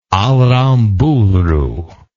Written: aramburu

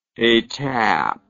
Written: etape

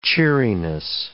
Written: cheeriness

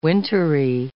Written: wintery

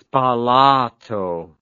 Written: spalato

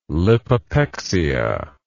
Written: lipopexia

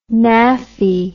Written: naffy

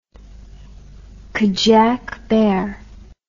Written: Kadiak bear

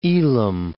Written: Elam

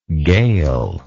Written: gail

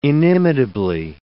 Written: inimitably

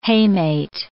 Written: hamate

